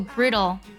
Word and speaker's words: brittle: 有个单词呢非常的好玩，它读作 brittle，特别要注意一下词尾 T-T-L-E，在美音当中它会读作 dull， brittle 这个是什么意思呢？